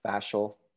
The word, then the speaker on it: fascial: You're talking about fascial. Is I think the way I would pronounce that. – Fascial, yeah, yes.